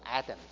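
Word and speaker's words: Adam (אדם): 因此神将他的性情，神将他的所谓的美德吹在他的里面，于是他成为有灵的活人，称他为人，英文翻译作 Adam，因为那个人在希伯来文的发音就叫 Adam。这样我们今天把 Adam 当作一个名词，懂吗？这个人叫约翰，这个人叫亚当，当作一个名词。但 Adam 在希伯来原文就是人的意思，称他为人，对吗？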